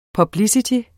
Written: publicity

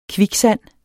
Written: kviksand

